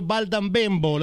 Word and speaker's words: Baldan Bembo: Siamo nella casa del grande Dario Baldambembo, l'ho detto giusto il nome? E vai, certo, certo, certo.